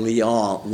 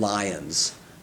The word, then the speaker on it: Lyon: I mean, you know, but he insisted on calling Marseille, Marseilles. And Lyon, Lyons. Because that's the way it's pronounced in Britain.